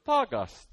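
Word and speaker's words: Thagaste: I didn't expect it to start talking to me, So Thagast is how you say it.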